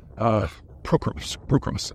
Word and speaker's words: Pokrovsk: Pokrovsk. Emphasis on the pro.